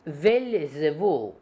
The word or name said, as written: Beelzebub